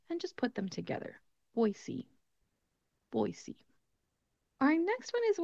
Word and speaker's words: Boise: When you see this city name, you might think it would be pronounced Boise or Boise, something around those terms, but instead it's pronounced Boise. Boise. So sometimes people will pronounce this word with a Z sound, like Boise. That's not how we're going to pronounce it. We're going to keep it as an S sound.